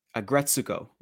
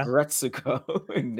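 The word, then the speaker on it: Aggretsuko: Agretsuko. There we go. I actually said the name. I was like, I have to remember how to say it. It's aggression. – Yeah. – Agretsuko.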